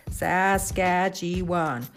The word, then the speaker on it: Saskatchewan: I listened intently, leaning in, waiting patiently for them to finish sounding out all four syllables Saskatchewan.